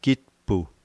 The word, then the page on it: kitpu: slow